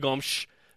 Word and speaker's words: Gomes: Ashley Hatch, Elena Medeiros, Michelle Vasconcelos, and Nadia Gomes. Nadia Gomes. BYU plays at UCLA tomorrow. Yeah, she's from Portugal, so you can say it like that.